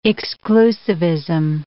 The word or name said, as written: exclusivism